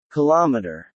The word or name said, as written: kilometer